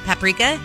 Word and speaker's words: paprika: You know there's not an extra A in there. – I know that now. But I found another word that I have been mispronouncing my entire life, and I'm too old now. It's how are you supposed to say it? – Paprika. – Right, paprika. Paprika. – You always add an extra letter.